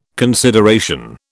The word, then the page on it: consideration: British English